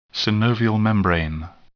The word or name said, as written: synovial membrane